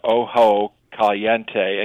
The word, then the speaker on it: Ojo Caliente: They say you've got to go to Ojo Caliente, and you did pronounce it correctly. It's spelled OJO, Caliente Mineral Springs Resort and Spa.